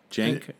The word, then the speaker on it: Cenk: Okay, it's Jenk, like Jenkins. – Jenk? – Uger. Uger, like Y-O-U-G-E-R-R-R-R-R-R-R.